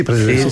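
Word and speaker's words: Président: Podré decir la marca, es que a mí me encanta la mantequilla y el queso Presidente. No sé si sí. – Se dice así. – Sí, sí, sí, Presidente.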